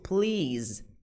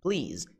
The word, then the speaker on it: please: Gente, cuidado com a pronúncia da palavra por favor em inglês, please, please, e não please, tá, gente? Não é esse som de S, não é please, é please, é som de Z, right?